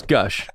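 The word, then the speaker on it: g'USH: It was just it was a random name generator, except it was spelled like lowercase g apostrophe capital U S H. Like Gaoosh or something like that. – Gaoosh. – I was like, oh, it's Gush.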